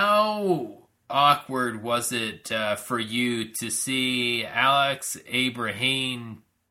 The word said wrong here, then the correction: Abrahantes: I can't even pronunciate his last name. Abrahantes? Yeah, there we go.